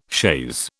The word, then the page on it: chaise: British English